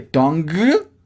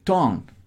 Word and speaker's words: tongue: Como que el g de tongue apenas si suena muy leve. Tongue.